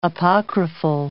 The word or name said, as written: apocryphal